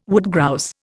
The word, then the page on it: woodgrouse: Indian